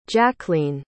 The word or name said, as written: jackleen